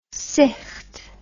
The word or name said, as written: sicht